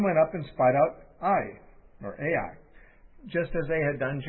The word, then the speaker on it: Ai: Verse 2, now Joshua sent men from Jericho to Ai, probably pronounced I, but I'm in the habit of saying Ai, so that's how I'm going to pronounce it,